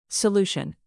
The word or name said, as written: solution